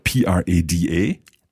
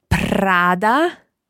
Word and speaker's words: Prada: What about the other one that's spelled P R A D A? – Prada.